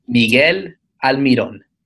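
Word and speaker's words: Miguel Almirón: Okay, so I'll say it in the most Paraguayan Spanish, because that's apparently the only Spanish I know that you could think of him, that I could say his name. So, one last time, I guess, for everyone that's listening, Miguel Almiron.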